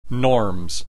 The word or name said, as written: norms